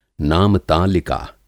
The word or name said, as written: नामतालिका